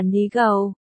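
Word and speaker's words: ni gâu (泥垢): Phát âm là ni gâu, tương tự với từ ni dầu.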